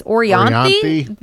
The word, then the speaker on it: Orianthi: I'm going to mispronounce this. Orianthi? Yes. Good.